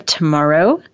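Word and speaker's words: Tomaro: You are listening to Real Real Estate Today, and I am your host, Deb Tomorrow. That's T-O-M-A-R-O.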